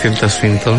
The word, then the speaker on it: Tilda Swinton: Performances Tilda Swinton.